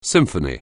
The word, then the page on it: symphony: anglais